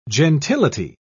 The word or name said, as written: gentility